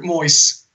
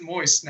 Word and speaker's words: Moyes: Moyes. Hold on. I remembered that you're actually supposed to pronounce it Moyes now. seriously, they've been calling him Moyes in America like forever.